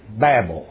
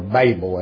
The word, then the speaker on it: Babel: And our study Wednesday night in Genesis, we looked at the city and tower of Babel, or Babel as it's supposed to be pronounced.